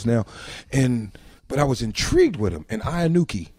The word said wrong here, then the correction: Inoki: Is that how you pronounce his name? – Inoki. – Inoki. – Yeah. – There you go. – Yeah, Inoki.